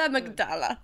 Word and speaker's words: amygdala: It's called the amygdala.